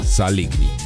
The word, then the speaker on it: Saligny: Așa că eu cred că ar trebui să-i pronunțăm numele în limba română, Saligny.